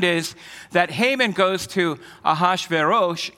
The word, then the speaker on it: Ahasuerus: The Persian name of Xerxes is Ahasuerus. So I was close, not completely on it, but Ahasuerus.